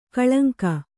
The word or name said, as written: ಕಳಂಕ